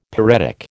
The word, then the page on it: pyretic: American English